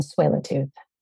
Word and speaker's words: Tsleil-Waututh: Musqueam, Squamish, and Tsleil-Waututh. – Tsleil-Waututh.